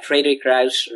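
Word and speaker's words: Frederik Ruysch: Frederick Ruysch. Is that? – Uh, I think that that's fairly okay. I think, yeah, Frederick Ruysch, Ruysch, yeah, Right.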